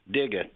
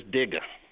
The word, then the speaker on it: Dega: Digga, D-E-G-A. World says Dega, but it is Digga.